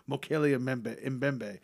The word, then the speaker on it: Mokele Mbembe: The monster, from which the local natives refer to as Mokele Mbembe, share many characteristics. Did I just butcher that again? – No, you got it right on.